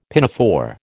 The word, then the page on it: pinafore: American English